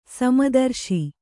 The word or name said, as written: ಸಮದರ್ಶಿ